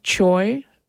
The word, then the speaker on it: Choi: Yi-Young Choi, which rhymes with boy, on the clarinet, and John Mayhood on piano.